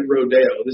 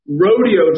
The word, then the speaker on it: Rodeo: I'm not going to say it Rodeo. This is America. We're going to use the American pronunciation. Beverly Hills luxury retail mecca Rodeo Drive will be closed to cars and pedestrian traffic on election day and the day after.